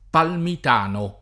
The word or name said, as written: Palmitano